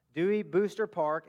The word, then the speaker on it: Dewey Booster Park: From 6 to 8 p.m., and it is at Dewey Oboster Park. Am I saying that right? Booster. Thank you. Dewey Booster Park in Deltona.